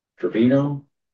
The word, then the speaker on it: Trevino: Trevino. – How do you spell it? – It's T-R-E-V-I-N-O.